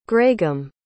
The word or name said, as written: graeghamm